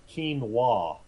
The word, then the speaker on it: quinoa: By the way, in case you were curious, it is pronounced quinoa.